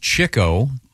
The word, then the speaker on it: Chico: Chico, it's pronounced Chico, really.